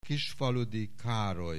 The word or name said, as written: Kisfaludy Karoly